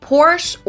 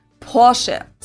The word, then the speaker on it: Porsche: I've heard Americans pronounce it Porsche or Porsche. – Porsche? Americans, what are you doing? – In German, we say Porsche. So there's like this short A sound in the end.